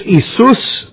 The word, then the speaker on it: Iesous: And if you look it up, by the way, in the Greek, it's Isus, which is spelled I-E-S-O-U-S, Isus Christos.